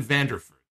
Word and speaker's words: Vanderford: I'm going to try to say his name again. Austin Vandervleur. Vanderford. There it is. Vandervleuren. No, it's Austin Vanderford.